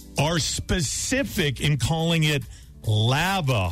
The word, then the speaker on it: Lava Hot Springs: The pronunciation of your city. – Lava Hot Springs. – People call it Lava Hot Springs and Lava Hot Springs. – Yeah, it's lava.